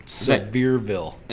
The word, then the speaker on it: Sevierville: Sevierville. – It's Sevierville? I thought I was going to pronounce it Sevier. It looks French.